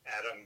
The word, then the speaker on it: Adam: Adam Bertrand, A-D-A-M, Bertrand, B-E-R-T-R-A-N-D.